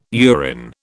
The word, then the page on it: urine: British English